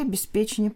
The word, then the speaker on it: обеспечение: Но вообще обеспечение правильно.